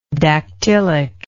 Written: dactylically

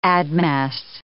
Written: admass

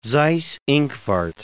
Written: Seyss-Inquart

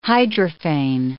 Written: hydrophane

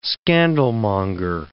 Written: scandalmonger

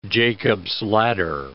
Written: Jacob's ladder

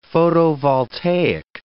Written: photovoltaic

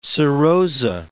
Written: serosa

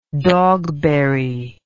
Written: Dogberry

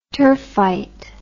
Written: turfite

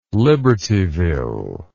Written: libertyville

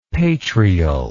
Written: patrial

